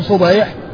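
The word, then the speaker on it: صُبَيْح: فالنعمان بن صبيح والده مفتوح بفتح الصاد وكسر الباء، وأما أبو الضحى مسلم بن صبيح فأبوه بضم الصاد وفتح الباء.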